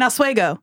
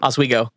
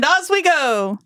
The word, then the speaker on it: Oswego: That's not how it's pronounced, but yeah. – It's not? – No. – How do you say it? – Oswego. – And Oswego. – Exactly.